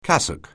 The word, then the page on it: cassock: anglais